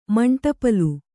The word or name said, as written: ಮಂಟಪಲು